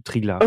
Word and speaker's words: Triglav: Also Triglav. – Okay. Ich werde manchmal unter YouTube-Videos nämlich berichtigt, dass es Triglau heiße. Okay. Also ausgesprochen. Aber ich nenne es auch Triglav.